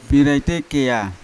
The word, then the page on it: biraite kea: Yawa